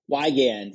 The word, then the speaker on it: Wygand: Is it Wagon or Wagon? – Wygand. Yeah, it's a weird Wygand. One. Someone butchered our German name way back when.